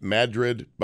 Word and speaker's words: Madrid: It's spelled like Madrid, Spain, but it's pronounced Madrid by the locals.